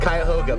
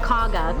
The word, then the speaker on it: Cuyahoga: Cuyahoga, but people who don't know it say Cuyahoga. – Yeah. Locals call it Cauga, Cauga Falls.